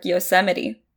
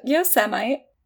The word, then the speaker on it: Yosemite: Do you know how he pronounced it? – Yosemite or something like that. – Yeah, Yosemite. – Oh. – Like, yo, Semite, where my Jews at?